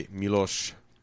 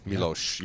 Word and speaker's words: Miloš: We're talking about the film Amadeus, directed by Miloš Forman, right? – Good pronunciation. – Miloš, yeah.